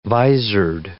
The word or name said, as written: visored